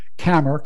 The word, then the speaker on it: Kammer: Jack Kammer, K-A-M-M-E-R. Sounds like Jack Hammer, right?